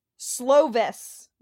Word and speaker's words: Slovis: Slovis. Am I saying that right? I hope I am. – Yeah, that's right.